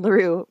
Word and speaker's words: LeRue: So my business is called LaRue Creative, and LaRue might sound like a French word, but it's actually my middle name. So it's spelled L-E-R-U-E.